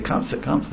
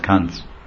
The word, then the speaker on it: Kamsa: In Bengali, Kamsa is Kamsa. In Bengali is Kamsa, and Sanskrit is Kamsa. But Hindi becomes Kans.